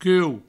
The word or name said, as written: cuou